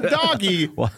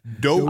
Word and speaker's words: dogie: Not doggie, dogie.